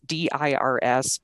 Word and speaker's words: Dirs: The Oliver, sorry, the author's name is Oliver Durs, Dirs, but pronounced like Sirs.